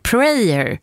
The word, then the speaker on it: prayer: ordet prayer, prayer rimmar på här, inte prayer, har hon skrivit.